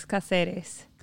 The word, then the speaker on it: Caceres: Alex Caceres.